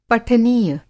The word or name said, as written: पठनीय